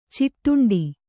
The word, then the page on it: ಚಿಟ್ಟುಂಡೆ: ciṭṭunḍe